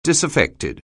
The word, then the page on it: disaffected: anglais